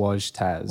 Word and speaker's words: Wojtas: Spelled W-O-J-T-A-S. Looks correct. Like Wojtas. – Wojtas, yes. I get Wojtas a lot. – Wojtas. – Everyone assumes the J is like Mexican.